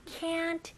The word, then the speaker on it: can't: 네, can't 라고 할 때는 모음을 좀 더 길게 해주시고 T까지 꼭 발음해주셔야 됩니다.